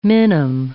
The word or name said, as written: minim